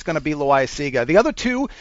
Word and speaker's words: Loaisiga: Jonathan Loaisiga. I don't know if I said it right. – Loaisiga.